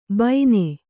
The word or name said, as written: ಬೈನೆ